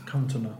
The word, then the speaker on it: Cantona: Eric Cantona, right? Is that pronounce it? – Cantona.